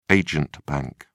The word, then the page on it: agent bank: British English